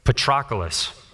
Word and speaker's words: Patroclus: I've said this name like 50 times getting ready for this sermon, and I still am not sure I'm going to say it right. Patroclus. Patroclus. Thank you.